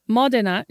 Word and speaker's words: Modena: We are brought to Modena. Is that how you pronounce it? – Yeah.